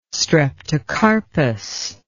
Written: streptocarpus

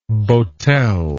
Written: botel

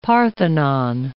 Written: Parthenon

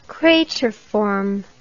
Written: crateriform

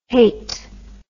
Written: Haight

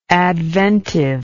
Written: adventively

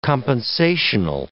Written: compensational

